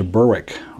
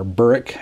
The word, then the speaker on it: Berwick: And we spent 3 years there, and then we moved to Berwick, or Berwick, as the locals say, Berwick,